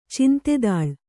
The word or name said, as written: ಚಿಂತೆದಾಳ್